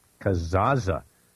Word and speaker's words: Kazaa: Kazaa. You pronounce both Z's. – Yes, okay. – You do. Kind of like pizza, I was told.